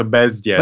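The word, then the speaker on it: Bezděz: It's a very soft D, but it's not a yeah. So Bezdez.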